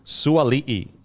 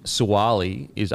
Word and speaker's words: Suali'i: Suali'i. I think Suali is actually pronounced Suali'i. So kind of like Papali'i.